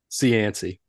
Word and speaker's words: Cianci: Cianci.